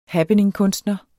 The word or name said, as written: happeningkunstner